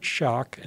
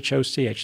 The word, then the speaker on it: Schoch: If I could put a plug in, if people go to my website, Robert Schoch, and that's Robert Schoch.